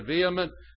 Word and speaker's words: vehement: And it came to pass, when the sun did arise, that God prepared a vehement east wind. The H is not pronounced. Vehement east wind.